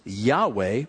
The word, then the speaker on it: Yahweh: But the word for God here is the Tetragrammaton, which is more commonly pronounced Yahweh, but the Jehovah Witnesses have commandeered this term.